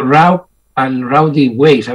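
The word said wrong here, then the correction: Rough and Rowdy Ways: Sería Roof and Rowdy Ways.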